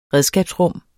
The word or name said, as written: redskabsrum